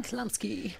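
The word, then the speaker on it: Chlumsky: Und jetzt müssen wir einmal kurz noch über Anna, Anna wie spricht man die aus? Klamski? Klamski.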